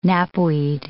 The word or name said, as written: knapweed